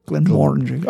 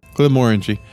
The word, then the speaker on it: Glenmorangie: No, Glenmorangie. Oh, yeah, you're right. – I did say it right for a change, and you ruined it. I fucking said it right for the first time. – Well, say it again. – Random belligerence. Say it right twice in a row, I dare you, edition. – Glenmorangie. – Very nice.